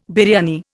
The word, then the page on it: biryani: Indian